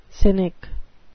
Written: cynic

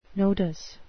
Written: nodus

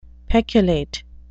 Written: peculate

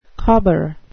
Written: cobber

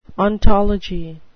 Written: ontology

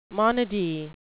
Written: monody

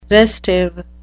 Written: restive

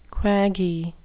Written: quaggy

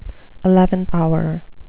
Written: eleventh hour

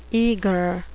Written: eagre

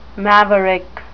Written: maverick

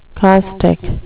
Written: caustic

